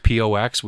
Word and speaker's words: pox: a spirit called pos. It's pr- pronounced pox, which is made with corn.